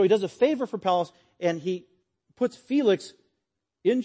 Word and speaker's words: Pallas: And Felix had an influential brother, Pallas, rhymes with Dallas, who happened to be best buddies with Claudius the Emperor.